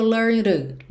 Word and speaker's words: learned: Learned to ficou learned, learned. Porque o ed learned não é pronunciado, o D é consoante oclusiva, pode aparecer mais, menos ou nada, e também já pode ligar no T do to, só que esse T do to foi reduzido para rararã.